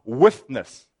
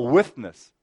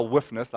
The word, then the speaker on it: withness: Our withness. I haven't mispronounced it. Our withness. Not our whiffness like a bad smell.